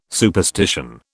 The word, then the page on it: superstition: British English